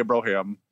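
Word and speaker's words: Brohawn: And shortly, a week after that, maybe not quite as big as Randy Johnson, Troy Brownham. Or Brohan. I don't know how to pronounce his name. – It's definitely a Brohan.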